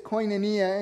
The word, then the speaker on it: koinonia: But the word is koinonia, and simply, it means fellowship. But koinonia is a deeper form of fellowship. It's a bond that Jesus followers experience as a result of our faith. Koinonia is deeper than just shared belief.